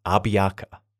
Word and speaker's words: Abiaka: The other man who I've been fascinated about since I learnt about him was Abiaka. It's often spelt many different ways, but I'll be pronouncing it as A-B-I-A-K-A, Abiaka, also known by his American name, Sam Jones.